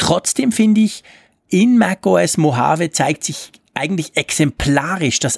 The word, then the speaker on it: Mojave: E- e- Mojave.